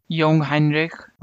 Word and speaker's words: Jungheinrich: Jungheinrich.